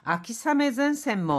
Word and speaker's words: 秋雨前線: 자, 여기 비 우자를요, 아메가 아니라 사메라고 읽습니다. 그래서 아키사메젠센 뭐 가을 장마 전선도 얏또 쇼메츠시다 미다이데스요.